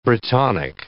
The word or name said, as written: Brittonic